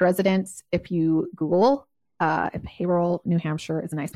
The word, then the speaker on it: Haverhill: The way it's spelt is not the way it's said. – English is hard. – Well, listen, it's spelt Haverhill, okay? Haverhill, okay. New Hampshire, but they call it Haverhill.